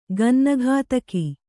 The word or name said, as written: ಗನ್ನಗತಕ